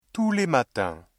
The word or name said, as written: tous les matins